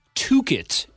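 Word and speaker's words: Tocchet: Rick Tukid was hired as Boudreau's replacement. Honestly, though, in their defense, I'm pretty sure Rick Tuket is the proper Canadian pronunciation of his name.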